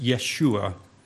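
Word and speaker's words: Yeshua (ישוע): His name of Jesus was highly appropriate when we consider Jesus' actual Hebrew name is actually pronounced Yeshua.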